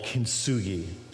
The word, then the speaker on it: kintsugi: In Japan, there is this art form called kintsugi.